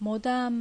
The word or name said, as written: Modan